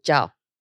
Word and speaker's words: Jiao: Because you got a J-I, yeah. – but you pronounce it... – Jiao. – Jiao. To an yeah. American, that sounds like a J-A. – Yeah. – Or J-O. Or yeah. J-O-U. Or J-A-U. – J-O-W.